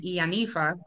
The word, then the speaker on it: Iyanifa: She earned a PhD from Harvard University and an MA in history from the City College of New York, an initiate of Obatala and Ianifa. Did I say that right? – Yes.